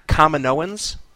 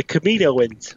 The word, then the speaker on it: Kaminoans: Kaminoans? Which are the... It's the Star Wars race that built the clone army for the Republic. – Oh, the Kaminoans. – That's it. I didn't pronounce it right.